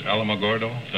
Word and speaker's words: Alamogordo: You were born in, is it pronounced Alamogordo? – That's right.